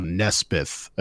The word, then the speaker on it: Nespithe: Nespithe.